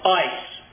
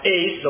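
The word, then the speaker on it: ice (εἰς): It's pronounced ice. Well, it's pronounced ice depending on who you talk to. It might be pronounced ace. Some people say ace.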